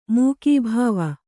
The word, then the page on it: ಮುಖಭಾವ: Kannada